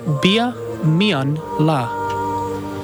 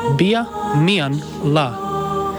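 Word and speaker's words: biadh meadhan-latha: And the word for lunch, a little harder, is bia mian la. Kind of three syllables there. Bia mian la.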